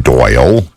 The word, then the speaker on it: Doyle: On my Facebook post of the night, you're going to find that on my page, Doyle King, on Facebook. My first name is spelled D-O-Y-L-E.